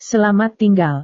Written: selamat tinggal

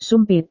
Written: sumpit

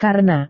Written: karena

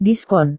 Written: diskon

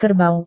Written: kerbau

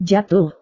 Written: jatuh

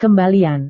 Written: kembalian

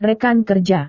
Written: rekan kerja

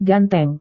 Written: ganteng

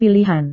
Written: pilihan

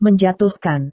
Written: menjatuhkan

